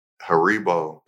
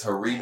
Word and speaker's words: Haribo: Haribo. – It's Haribo.